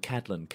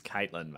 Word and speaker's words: Catelyn: Yeah, it's always the way when you read these characters and then suddenly you're like, that's not how you pronounce Caitlin. Or like, Catelyn. I'm like, no, it's Caitlin, mate.